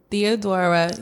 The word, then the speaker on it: Theodora: So the Americans, we know how to say it profoundly the Haitian way. Mm-hmm. It's Theodora Ulysse Joseph.